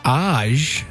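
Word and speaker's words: Ajé: Age? – Yeah, A-G-E. A-J-E.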